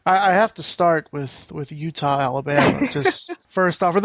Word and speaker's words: Eutaw: And also, it is not spelled like the state. It is actually exactly. – E-U-T-A-W.